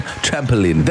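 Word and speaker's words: trampolín: Yes, which is the Spanish word for diving board. Said exactly like that. That is the Spanish pronunciation. Trampoline.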